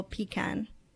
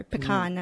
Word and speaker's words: pecan: And our new one, which is Eastern Apple Pecan or pecan. I'm not really sure how to pronounce it. – People say it either way. – They say it both ways.